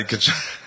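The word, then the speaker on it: Tkachuk: Kachuk? Is that how you pronounce it? – Yeah. Matty Kachuk.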